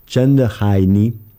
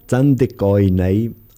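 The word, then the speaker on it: Chen de Hai Ni (真的愛你): Pour le titre de la chanson qui se prononce « Chen de Hai Ni » en mandarin et « Tan de Koi Nei » en cantonais, ça veut dire dans les deux dialectes « Je t'aime vraiment ».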